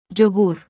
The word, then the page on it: y: Spanish